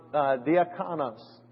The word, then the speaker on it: diakonos: In the Greek, it's pronounced diakonos.